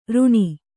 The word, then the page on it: ರುಣಿ: ruṇi